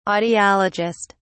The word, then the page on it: audiologist: British English